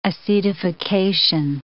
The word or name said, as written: acetification